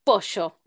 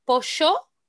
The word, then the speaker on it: pollo: But one of the things, if you're learning Spanish, it's okay to pronounce pollo or pollo. However, if you're going to pronounce the double L as a sh or a y, you have to pronounce the Y in the same sentence as a sh or a y.